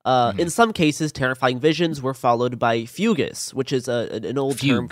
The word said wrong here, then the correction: fugue: It says fugues. – Fugue. It's pronounced fugue.